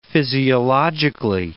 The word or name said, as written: physiologically